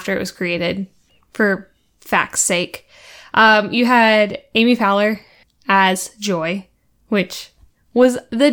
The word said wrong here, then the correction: Poehler: Amy Poehler.